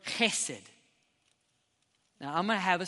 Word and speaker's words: chesed: If you have a little spittle when you say it, you know you're saying it correctly. It's not he. It's not H. It's a ch. Okay? Ready? So, everyone with me, chesed.